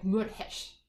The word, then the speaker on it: Meurehg: I don't pronounce it properly, by the way, because it's in Arabic, right? So I'm going to give it a try, how my grandfather used to pronounce it, but I'm really bad at it. So excuse me, all the Meurehg family. So it would be something like Meurehg.